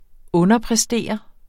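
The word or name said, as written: underpræstere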